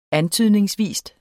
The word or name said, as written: antydningsvis